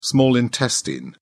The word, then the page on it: small intestine: British English